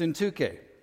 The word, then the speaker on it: Syntyche: And I said, well, yeah, it does sound like a tech company, like Syntech. The evil Syntech needs to be taken down. But it's Syntyche. So that's how we'll pronounce it.